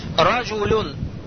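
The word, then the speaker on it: Раджулюн (رجلٌ): Раджулюн. Раджулюн. Слышите, да, на одной сукун? Однако мы же, когда раджулюн пишем, не видим же этот нун с сукуном.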